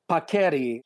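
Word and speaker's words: paccheri: How do you pronounce it? Paquera? – Paqueri.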